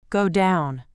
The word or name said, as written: go down